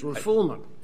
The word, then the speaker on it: refoulement: You mispronounced it the last time you were here. It's refoulement.